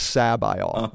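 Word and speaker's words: C'baoth: And Zahn said that he pronounced it Sabaoth.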